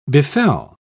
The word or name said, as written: befell